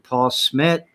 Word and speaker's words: Paul Schmidt: And today I've got somebody, Mr. Paul Schmidt.